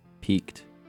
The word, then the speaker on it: piqued: And before that, you were listening to Jeffrey Runnings, the track called Threadbare from his full length called Peaked. But that's spelled piqued, like a little French, like P-I-Q-U-E-D.